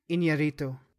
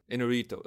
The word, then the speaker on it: Iñárritu: He's Alejandro Iñárritu. – Inarito.